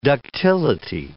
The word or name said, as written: ductility